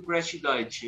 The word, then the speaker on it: Crash Diet: Me corrijam se eu pronunciar errado o nome da banda, por favor, porque né, meu inglês é horrível mas tem a Crash Crash Diet. – Crash Diet.